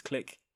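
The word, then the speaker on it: Klich: I've been saying his name wrong this whole time. I keep saying Cliche. His name is Click.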